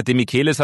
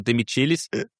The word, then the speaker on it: Demichelis: Demichelis hat gesagt, eigentlich heißt er Demichelis.